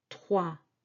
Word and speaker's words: trois: OI together, these two vowels, is wa, trois, and this S is silent.